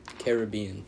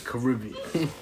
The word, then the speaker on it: Caribbean: Caribbean. – The Caribbean. – Hey, you can pronounce it either way. – I guess. – There's no incorrect or correct answer.